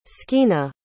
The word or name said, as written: Skeena